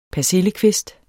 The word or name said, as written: persillekvist